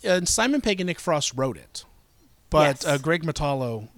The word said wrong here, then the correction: Mottola: is that how you say his name? He directed Greg Mottola, yeah. Mottola.